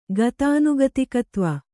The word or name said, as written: ಗತಾನುಗತಿಕತ್ವ